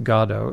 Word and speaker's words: Godot: And so here he's written this thing, and in France, G-O-D-O-T would not be pronounced Godot, it would be pronounced Godot.